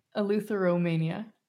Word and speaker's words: Eleutheromania: We had a little, instruction on how to say this, Eleuthermania. Oh, am I close? – You're so close. That's like tell me how do you say it? – Eleutheromania. – Eleutheromania. Okay, Eleutheromania.